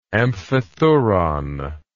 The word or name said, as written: amphithuron